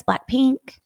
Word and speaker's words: Blackpink: Well, Maya likes Blackpink.